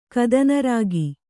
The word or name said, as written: ಕದನರಾಗಿ